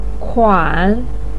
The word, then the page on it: kuan3: pinyin